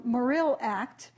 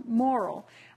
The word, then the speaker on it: Morrill: And Michael said, no, you know, when you look at the records and you look at the misspellings of the name when people started talking about the act in Congress, you have a sense that it was actually pronounced moral.